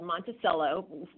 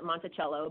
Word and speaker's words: Monticello: But my mom is from a small, small town in Kentucky called Monticello. The rest of the country would pronounce it Monticello, but in Kentucky we say Monticello.